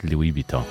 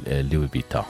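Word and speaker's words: Louis Vuitton: أيضا عندي أيضا شيخة شكرا على المسج تقول ماركت لوي فيتان, هو فعلا مثل ما ذكرت أحيانا تنطق, يعني البعض يعني ينطق الأس, البعض يقول لوي فيتان.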